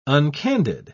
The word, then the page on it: uncandid: British English